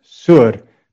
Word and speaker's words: Tyre: T-Y-R-E. But it's not pronounced Tyr. It's pronounced Sur, because that Sadi is like a T-S.